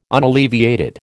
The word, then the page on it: unalleviated: American English